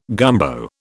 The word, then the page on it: gumbo: British English